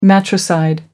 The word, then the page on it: matricide: American English